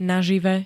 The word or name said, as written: nažive